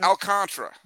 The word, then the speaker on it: Alcantara: Is it not Alcantara? Is it Alcantara? – It's Alcantara.